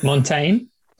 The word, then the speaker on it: Montaigne: Montaigne.